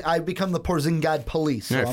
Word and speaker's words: Porzingis: I know, it's Porzingis.